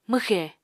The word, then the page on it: mwxè: Apatani